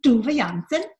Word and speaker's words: Tove Jansson: This is, it's spelled T-O-V-E. And the last name is J-A-N-S-S-O-N, and that's what Wikipedia tells me is the correct pronunciation, Tove Jansson.